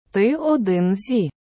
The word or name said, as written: Ти один зі